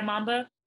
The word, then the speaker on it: Momba: It says M-O-M-B-A, Mamba, right? Or pronounce it for me. – Yeah, Mamba.